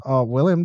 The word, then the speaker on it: Willem: Willem Dafoe. There's a debate about that, but it's Willem to me.